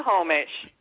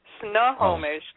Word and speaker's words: Snohomish: Nahomish. – It's not spelled Nahomish. It's spelled... – No, Snohomish. Snohomish. – Snuh.